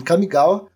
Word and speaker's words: Kamigawa: Faz quase um ano aí, que ela vem em Kamigawa.